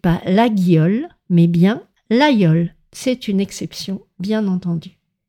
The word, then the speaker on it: Laguiole: D'ailleurs, en parlant de couteau, ne vous trompez pas pour la prononciation du célèbre laïol qui s'écrit L-A-G-U-I-O-L-E, qui est un couteau très connu fabriqué dans l'Aveyron en France. On prononce le G comme un Y, le laïol.